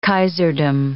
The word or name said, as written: kaiserdom